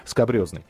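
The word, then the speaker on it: скабрезный: Э, есть такой, есть, появился анекдот уже такой, э, может быть, он немножко скобрезный, но тем не менее скобрезный, правильно говорить.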